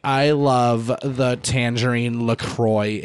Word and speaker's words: LaCroix: It's not fucking LaCroix, white fucking soccer moms and Kroger. It's LaCroix. Just because you're not French doesn't mean you can change the fucking pronunciation.